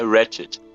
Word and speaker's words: Ratchet: É, Ratchet. É que tem o T do, o T do final, né? – Ratchet Sim, é, então é, é um trava-língua.